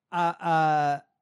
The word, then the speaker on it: Abdulrahman: How do you say it? What is it? – Abdulrahman. Abdulrahman Muhammad.